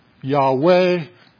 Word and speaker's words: Yahweh: And remember, okay, so Yahweh, YHWH in biblical Hebrew, we transliterate this, turn it into English, and pronounce it Yahweh.